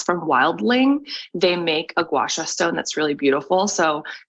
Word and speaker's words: gua sha: So most people have the instinct to gua sha way too hard.